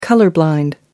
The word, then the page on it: colour blind: American English